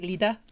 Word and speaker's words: Lieder: L-I-E-D-E-R. – Ah, yes. – Did I pronounce it correctly, Lida? – Yes.